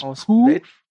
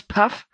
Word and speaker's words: Pugh: Florence Pugh. – Ich will immer Puff sagen. – Florence Puff.